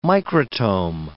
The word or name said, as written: microtome